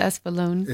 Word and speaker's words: Espolone: I was actually going to say it wrong. Espolone. – No, it's Espolone.